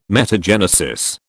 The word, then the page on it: metagenesis: British English